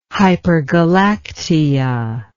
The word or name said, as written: hypergalactia